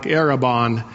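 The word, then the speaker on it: arrabon: In fact, the Greek word and the Hebrew word have almost the same pronunciation. It sounds kind of like Erebon.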